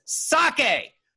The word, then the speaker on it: sake: Two, the pronunciation is sake. Sake.